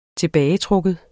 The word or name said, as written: TILBAGETRUKKEN